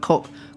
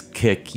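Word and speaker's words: koek: I'm going to pronounce it all wrong, but cook, yeah. – I think it would, and I should say, because Dutch is pronounced in a way that I don't understand. But as best as I know, it's kick, yeah. – All right, that's close enough. – It's close enough.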